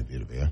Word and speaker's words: Vita Vea: Is that not how you say his name? – You just say Vita Vea.